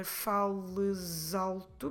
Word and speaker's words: fales alto: Notice that I'm putting together fales alto because fales ends in S. So that S is between the two vowels, so I can pronounce it like a Z sound.